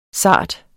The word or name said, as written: SARTESTE